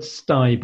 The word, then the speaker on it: sthie: The word sty, by the way, is a Manx word and it means at home. It's spelled S-T-H-I-E. So it reads a little bit like sty at home, so stay at home.